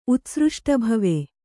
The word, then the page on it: ಉತ್ಸೃಷ್ಟಭವೆ: utsřṣṭabhave